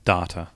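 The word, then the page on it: data: Australian English; New Zealand English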